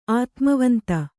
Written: ಆತ್ಮವಂತೆ

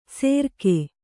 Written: ಸೆರ್ಕೆ

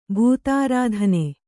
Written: ಬಿತ್ತರಿತನ

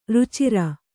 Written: ರುಚಿರೆ

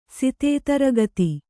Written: ಸಿತೇತರಗತಿ

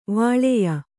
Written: ವೀಳೆಯ

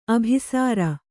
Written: ಅಭಿಸಾರ

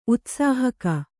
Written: ಉತ್ಸಾಹಕ

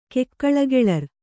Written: ಕೆಕ್ಕಳಗೆಳರ್

